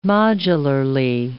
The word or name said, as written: modularly